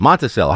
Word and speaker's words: Monticello: Monticello or Monticello? – Cello. – Yeah, that's what I thought. Monticello.